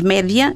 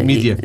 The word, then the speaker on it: média: Os média é, são os meios de comunicação e as pessoas pronunciam a inglesa não é? Eh, mídia. – Mídia, portanto, não tem nada a ver com o inglês.